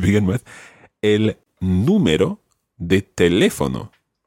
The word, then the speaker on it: teléfono: So if there was no accent on teléfono, it would be teléfono, but that's wrong. So it's teléfono.